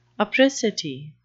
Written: apricity

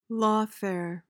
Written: lawfare

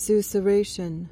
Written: susurration

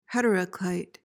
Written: heteroclite

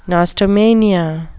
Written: nostomania

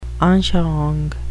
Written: anschauung

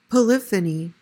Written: polyphony

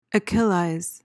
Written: achillize

